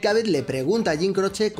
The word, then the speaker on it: Croce: I just have to ask you, how do you say your own name? It's spelled C-R-O-C-E. – Croce. – Croce. – Exactamente igual que lo está pronunciando Bang Bang.